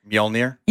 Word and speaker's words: Mjolnir: Mjolnir? – Yeah.